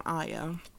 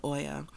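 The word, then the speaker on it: Oia: But actuality, he took me to the other side of the island, to the west side of the island, to Aya, or some people pronounce it Oya.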